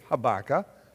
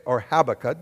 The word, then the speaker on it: Habakkuk: H-A-B period. Habakkuk. Okay? Or Habakkuk, depending on how you want to pronounce it.